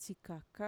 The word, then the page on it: txikaka: Mixtec